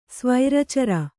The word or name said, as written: ಸ್ವೈರಚರ